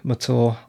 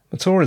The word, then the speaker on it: Mathur: My name is Roy Mathur. I'm a writer. Mathur is spelt M-A-T-H-U-R. If you don't pronounce it right, I will mock you mercilessly.